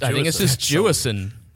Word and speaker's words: Jewison: How do we say his last name? Because I've been struggling with. – I think it's just Jewison.